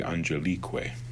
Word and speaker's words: angelique: And notice it's because the emphasis in angelique is on the E because it's a long vowel. If we had simply said angeli et arcangeli, it would have been on the on, but the emphasis is on the e because it's a long I.